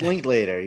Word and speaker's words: Linklater: Is that, how do you say it? – It's Linklater. You were right.